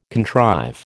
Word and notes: contrive: American English

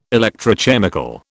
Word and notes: electrochemical: British English

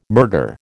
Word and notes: murder: American English

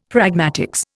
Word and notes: pragmatics: Indian

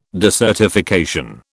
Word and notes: desertification: British English

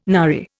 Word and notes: nary: Indian

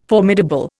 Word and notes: formidable: Indian

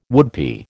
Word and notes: woodpie: American English

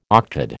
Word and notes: octad: American English

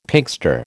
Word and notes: Pinkster: American English

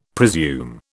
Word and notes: presume: British English

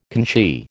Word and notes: conchie: American English

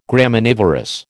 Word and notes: Graminivorous: American English

